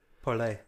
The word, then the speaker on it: Parler: Parlay, It's French for talk.